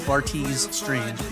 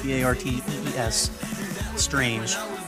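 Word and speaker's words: Bartees Strange: I believe it's pronounced Bartez Strange, Bartez Strange.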